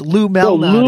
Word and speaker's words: Lou Malnati's: We go to Illuminati's? – Yeah, no, not the Illuminati. It's Lou Malnati's.